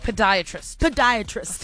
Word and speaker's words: podiatrist: We're talking to a foot doctor, also known as a pediatrist. Did I say that right? – Podiatrist. Foot doctor.